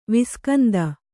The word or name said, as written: ವಿಸ್ಕಂದ